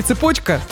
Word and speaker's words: цепочка: Ну да, кстати, это слово, которое часто вызывает вопросы в плане ударения, но все-таки «Цепочка».